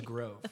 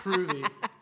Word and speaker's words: Grove: I was like, my name is Grove, not Groovy.